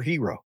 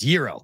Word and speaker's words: gyro: And hero is not right because there's no H in it. So it's gyro. The G is silent.